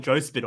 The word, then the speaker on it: JOSPT: Sorry, I think I butchered the name, but yeah, Joe, I call it Joe Spit all the time.